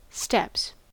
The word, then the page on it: steps: American English